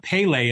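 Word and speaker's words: Pelé: So that Pele resonated with me big time. – There's a great clip of, and I've learned now it's not Pele, it's Pele. Because the accent is on the second E.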